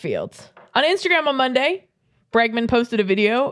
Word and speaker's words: Bregman: It's Bregman.